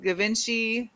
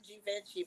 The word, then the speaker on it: Givenchy: I know everybody tries to pronounce Givenchy, but it's Givenchy. If you're gonna pay $650 for sneakers, you need to at least pronounce it right.